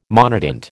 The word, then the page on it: monotint: American English